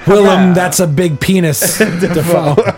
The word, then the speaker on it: Dafoe: By the way, he said on Conan the other day, they pulled up an old interview, his name is actually pronounced Dafoe.